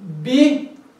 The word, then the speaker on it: بيع: مثلا بيع، نقول مثلا بيع، يعني أنت تحرك الشفتين باتجاه الضم بعد أن تنطق بالكسرة. بيع، يعني لا، الأصل أن لا يظهر صوت وإنما حركة الشفتين.